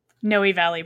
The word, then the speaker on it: Noe Valley: Is another side question based off of that, is, how do you say it was, Noe Valley? – Noe Valley Books, yeah.